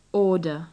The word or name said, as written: order